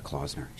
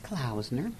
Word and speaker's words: Klausner: I called her Klausner. She says it's Klausner.